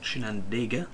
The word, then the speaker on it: Chinandega: That is Chinandega, to pronounce it correctly.